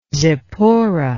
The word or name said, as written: zipporah